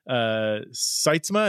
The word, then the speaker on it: Sytsma: You co-authored it with Dr. Michael Sytsma. Is that how you say his name? – Hey, very good. Absolutely. Yeah, most people get his last name wrong.